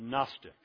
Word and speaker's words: Gnostics: They're called the Gnostics, the beginning of the Gnostics, spelled with a silent G. Silent G, N-O-S-T, and so on.